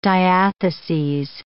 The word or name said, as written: diatheses